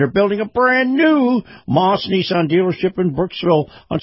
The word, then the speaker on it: Maus: And this portion of the program brought to you by Moss Family Automotive and Moss Nissan. Moss is spelled M-A-U-S.